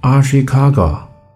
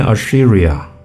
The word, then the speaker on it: 足利: 再说，足利竟然念作 Ashikaga，不是念 Assyria。